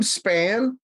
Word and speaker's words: Spann: Tim Spann, though that's been pronounced wrong. Sometimes I'm a pork product, you know, Tim. Or email you don't want.